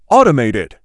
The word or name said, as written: automated